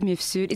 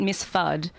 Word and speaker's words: Mifsud: You can say it like that, Mifsud. It's Maltese, so it's a little bit tricky. Yeah. It's not Miss Fudd.